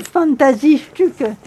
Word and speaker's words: Fantasiestücke: Mais c'est pas mal du tout, Fantasiestücke. Et la pièce est Aufschwung.